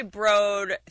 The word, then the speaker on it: Broad: It is spelled B-R-O-A-D. I mean, that's right. Eli Broad, thank you, caller, and get a job. the Eli Broad Theater is what it's called. It's not the Broad Theater. I'm sorry that I can phonetically read, but it is Broad.